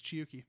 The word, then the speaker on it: Chiyuki: It's Chiyuki. – I know. I wrote it down. – And it's nice because it is actually a Japanese name that is spelled the way it sounds. Chi, C-H-I, Yuki, Y-U-K-I.